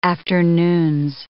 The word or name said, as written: afternoons